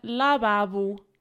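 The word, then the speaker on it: lavabo: Lababo. Have I said that right? – Sort of, yeah. Lababo. – Lababo. – Yeah. Again, that it ends in an O, but it's ooh, lababo.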